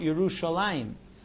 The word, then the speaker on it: Yerushalayim: We pronounce it Yerushalayim. But if you look at the spelling, it's always without the Yud, Yerushalayim, because it's a combination of Yeraeh and Shalem.